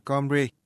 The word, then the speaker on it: Cymru: Họ nói nhiều giọng khác nhau, thậm chí người xứ Wales còn có cả ngôn ngữ riêng của họ, Cymru, viết là Cymru, nhưng mà đọc là Cymru.